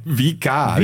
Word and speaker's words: Vikar: Vika. – Vika. Es wird doch mit W geschrieben. – Ja, wird aber auch wie W ausgesprochen.